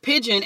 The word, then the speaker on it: pidgin: So pidgin, and it is not spelled like the bird. It is P-I-D-G-I-N. And so, but it's pronounced just like the bird pidgin, right?